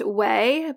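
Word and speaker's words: OUAI: The first one is it's pronounced Way, but it's O-U-A-I. It's that hair care brand Way.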